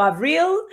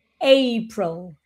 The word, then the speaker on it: April: Sí, hay un pequeño problema con April, ¿no? Que tenemos tendencia a decir April o April o Abril. Entonces es importante que este se diga April, April. – La muy bien. – A se pronuncia A.